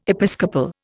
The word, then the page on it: episcopal: Indian